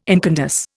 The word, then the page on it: incandesce: Indian